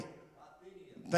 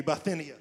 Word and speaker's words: Bithynia: Peter, an apostle of Jesus Christ, to the pilgrims of dispersion of Pontus, Galatia, Septimania, there we go, Asia, and say it for me. Bithynia. Thank you, Bithynia. I say it wrong every time. I listened to it 27 times today, and I said it wrong again.